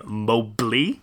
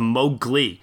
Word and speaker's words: Mowgli: And I want to just say, too, I used to make the mistake when I was younger of always saying Mowgli instead of Mowgli, which is the way that it's pronounced here.